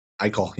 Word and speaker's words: Eichel: Eichel. Is that how you pronounce his last name? – Eichel.